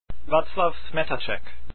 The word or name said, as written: Smetacek